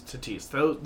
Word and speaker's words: Tatis: Is it Fernando Tatis? It's Tatis Jr. Yes. – It's Tatis. Those should be the American League and National League. – It's Fernando Tatis.